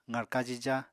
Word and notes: qarka jija: apatani

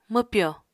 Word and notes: mwpyò: Apatani

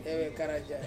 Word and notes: vekeraja: Ansus

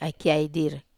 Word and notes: ai kiai dire: Wamesa

